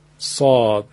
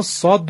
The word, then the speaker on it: صاد: حرف صاد عیناً مثل سین تلفظ میشه، فقط همراه سین صدا پرحجم و درشت می شود. سین را با صدای درشت بگیم میشه صاد.